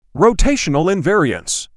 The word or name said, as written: Rotational invariance